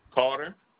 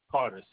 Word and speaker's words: Carter: It's Zachary Carter. – Is it Z-A-C-A-A-R-Y? – Z-A-C-H-A-R-Y. – I mean, yeah. I spelt it right, but said it wrong. Okay. And your last name? – Carter. C-A-R-T-E-R.